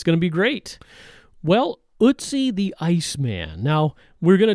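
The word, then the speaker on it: Ötzi: It's spelled O-T-Z-I, the Iceman. We looked up the pronunciation, and we think we're right on the way this is pronounced, Ootsie.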